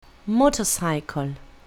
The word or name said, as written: motorcycle